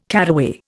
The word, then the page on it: caraway: Indian